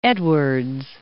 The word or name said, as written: Edwards